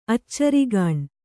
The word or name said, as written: ಅಚ್ಚರಿಗಾಣ್